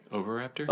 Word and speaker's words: oviraptor: The clutch of ancient eggs belongs to a medium-sized adult oviraptor. Did I pronounce that right? – Oviraptor? – Oviraptor? Yeah. Okay.